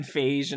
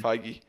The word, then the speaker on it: Feige: Like you should honestly kind of maybe tip your hat to Kevin Feige and all those people for keeping it going. – Feige. – For the, it's spelled like beige. – So, I know, but that doesn't mean it's still Feige, though.